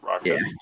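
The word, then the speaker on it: Rakić: This Saturday for Blachowicz versus Rakic. Is that how you say it? – Rakic.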